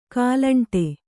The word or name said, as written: ಕಾಲಂಟೆ